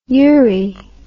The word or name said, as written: eury